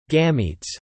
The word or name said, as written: gametes